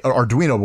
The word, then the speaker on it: Arduino: This is a Arduino, as it's pronounced, an Arduino board.